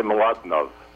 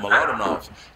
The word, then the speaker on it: Mlodinow: I would say Mlodinow. The W at the end sounds like a V, but that's just a little trick of my European ancestors. – Oh, Mlodinow.